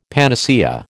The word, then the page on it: panacea: American English